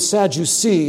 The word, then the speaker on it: Sadducee: It seems as if that name, Sadducee, of course, in English, we're softening the C because it comes before an E and all of that stuff, probably would have been pronounced something like Sadducaeus.